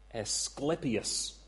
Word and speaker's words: Asclepius: You remember that symbol of ancient medicine, the pole with the snake or the serpent twisted around it, the rod of Asclepius.